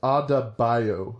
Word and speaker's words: Adebayo: How do you say Abadeo's name? Adebayo. – I wrote it down as Adebayo. – Yeah, Adebayo. That sounds better.